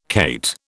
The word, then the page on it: cate: British English